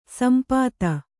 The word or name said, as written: ಸಂಪತ್ತಿ